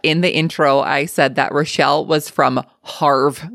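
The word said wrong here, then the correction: Havre: Montana, when in actuality, it is pronounced Haver, Montana.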